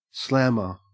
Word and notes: slammer: Australian English

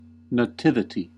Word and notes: nativity: American English

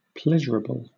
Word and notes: pleasurable: British English

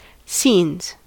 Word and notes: scenes: American English

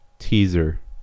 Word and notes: teaser: American English

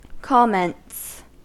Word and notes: comments: American English

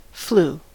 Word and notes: flu: American English

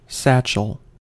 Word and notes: satchel: American English

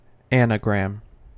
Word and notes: anagram: American English

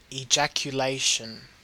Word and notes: ejaculation: Australian English

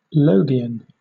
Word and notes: logion: British English

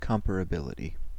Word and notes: comparability: American English

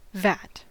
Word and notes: vat: American English

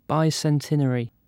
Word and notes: bicentenary: British English

